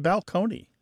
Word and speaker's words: balcony: My favorite change that I love to talk about when Martha and I go and do live events in the rows is the word balcony, you know, like this place on a stage that you can look down upon the seats, used to be pronounced balcony. – Oh, wow. Okay. – Yeah. Which people are like, what? How? Why did it? That doesn't make any sense. But yeah. But it's completely undergone the change, whereas other words are still in transition.